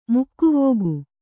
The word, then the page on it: ಮೊಗವಿಕ್ಕು: mogavikku